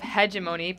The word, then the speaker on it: hegemony: So the idea of hegemony, pronounced with a hard or soft G, is the idea of power over one group or other groups.